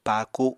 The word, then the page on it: paaku: Apatani